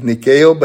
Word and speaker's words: Nike: N-I-K-E. Y'all get that? You know? In the Greek is nikeo, but in the English we call it what? Nike? Oh, yeah, you got it. Okay, you're listening. So we pronounce it Nike.